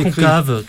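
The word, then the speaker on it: CONCAWE: Alors il y avait des experts sollicités sur les aspects environnementaux et sanitaires, et parmi eux un représentant de Concave, je sais pas comment le prononcer. – On va dire Concave, tout simplement. – Concave, ça veut dire conservation of clean air and water in Europe, préservation de l'air et de l'eau pure en Europe.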